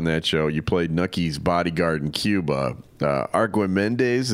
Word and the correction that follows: Archimedes: is that how you pronounce it? – Well, it's Archimedes.